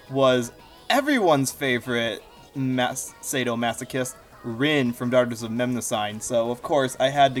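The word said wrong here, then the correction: Mnemosyne: First of all, it's pronounced Namasini.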